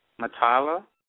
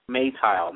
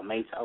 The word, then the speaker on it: Meital: Matala? Meital, M E I T A L. Oh, Meital. Is that is that correct? – Meital. – Maytile. – Maytile.